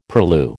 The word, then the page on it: purlieu: American English